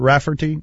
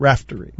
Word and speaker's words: Raftery: It's not Rafferty. It's Raftery.